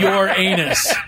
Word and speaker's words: Uranus: People do call it Uranus to avoid saying your anus, but.